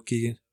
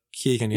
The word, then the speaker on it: Keoghan: I think Keoghan is just quick little Keoghan. Keoghan, yeah.